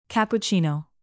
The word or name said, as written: cappuccino